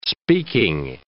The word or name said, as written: speaking